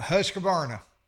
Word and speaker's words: Husqvarna: I can't pronounce that word. – Husqvarna. – I know how to pronounce it. I don't. – Yeah, Husqvarna. – Yes, sir. That's it.